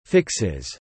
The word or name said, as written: fixes